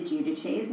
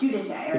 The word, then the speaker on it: Giudice: You know what really bothers me when people mispronounce Teresa Giudice's name? Oh. And she mispronounces her own name. Like that, it, should, it should be like Giudice or yeah.